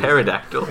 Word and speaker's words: pterodactyl: Pterodactyl. Pterodactyl. – The B is silent. – Yeah. – They actually use it with a P? – Yeah, it's pterodactyl, but it's the P is silent.